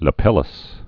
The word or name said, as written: lapilli